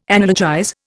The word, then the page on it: analogize: Indian